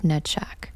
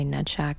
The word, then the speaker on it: Netzach: And I have to do a little sidebar here because it shows my immaturity level, because immediately when I say Netshak, all I think is nutsack, but that's not what it is. It is Netshak, I believe. And the word is spelled N-E-T-Z-A-C-H.